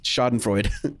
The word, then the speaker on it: Schadenfreude: Schadenfreude. That's what the last one's pronounced.